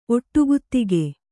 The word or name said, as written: ಒಟ್ಟುಗುತ್ತಿಗೆ